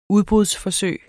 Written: udbrudsforsøg

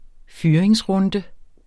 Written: fyringsrunde

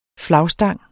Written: flagstang

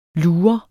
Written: LUREREN

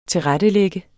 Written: TILRETTELAGT

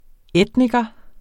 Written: etniker

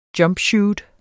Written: jumpsuit

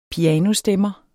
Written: pianostemmer

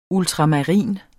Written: ultramarin

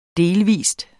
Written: Delvist

